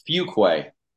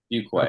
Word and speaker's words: Fuqua: Close. It's Fuqua. – Fuqua. – Fuqua.